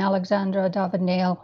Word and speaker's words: Alexandra David-Neel: I mean, Alexandra David-Neel, which is the way the French pronounce it,